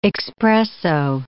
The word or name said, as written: expresso